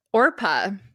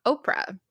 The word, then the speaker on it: Orpah: She's named after Orpah. After the biblical character in the book of Ruth, and everybody mispronounced it, calling her Oprah.